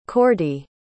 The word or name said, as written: Cordy